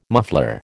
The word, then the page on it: muffler: American English